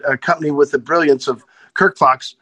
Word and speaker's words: Kirk Fox: It's you put the accent de vu on Kirk. It's Kirk Fox.